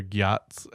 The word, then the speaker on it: gyat: Okay, so, but for real though, do people talk about their gyats at all? Is that how you say it, gyat? – Yes, that's how you say it.